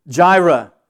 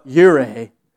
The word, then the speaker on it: Jireh: Jehovah Jireh, or Jehovah Yireh, however you should pronounce.